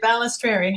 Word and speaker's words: Balestrieri: You did, Balestrieri.